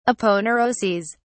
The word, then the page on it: aponeuroses: British English